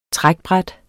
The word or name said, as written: trækbræt